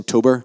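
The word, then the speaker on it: tobar: The word tabar, you know this, the Irish meaning of it, or the Irish pronunciation tober, I think is the proper way to say it.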